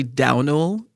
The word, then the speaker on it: Domhnall: And someone in the comments very kindly broke down how in Ireland, you can pronounce his name with like an SH or a W. So his name is actually Domhnall Gleeson.